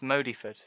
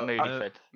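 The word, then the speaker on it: Modyford: A Sir Thomas Modiford. Sorry, not Moodyford.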